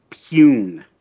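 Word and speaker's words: Pyun: I've heard it pronounced both ways. I believe the proper pronunciation is Payun.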